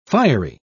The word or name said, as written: fiery